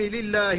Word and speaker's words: لله: ثم لام لله لنا، كذلك لا بد من الحذر من تفخيم لام لله. الحمد لله، لا تقول الحمد لله كما يفعل بعض الناس، فلا بد من التحفظ على النطق باللام المكسورة هنا. ولام الجر إذا كانت مع الضمير فتحت، وإذا كانت مع الاسم الظاهر كسرت، ومثل بهما معا.